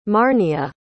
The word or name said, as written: marnia